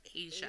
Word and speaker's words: Asa: A-S-A is pronounced Asia.